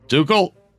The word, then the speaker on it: Tuchel: I have not heard Tuchel. – Tuchel. – There you go.